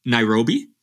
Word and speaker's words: Nairobi: So you went to an all-girls high school in, I hope I'm saying this right, Nairobi, Kenya? – That's right.